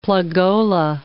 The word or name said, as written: plugola